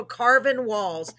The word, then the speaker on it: Carvin Walls: Yes, make sure y'all follow Carvin Walls, Carvin Walls, just like Walls is spelled. Carvin is not spelled. It's Carvin.